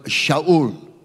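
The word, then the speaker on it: Shaul (שאול): Now, if you look at the word Saul, we also know that the word Shaul is the Hebrew pronunciation of it, Shaul.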